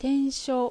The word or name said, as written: Tensho